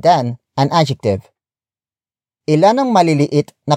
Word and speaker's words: maliliit: Word for word, it's ilan, ang, maliliit, na, kamatis, sa, basket.